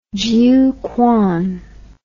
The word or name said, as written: jiuquan